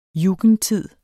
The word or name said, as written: jugendtid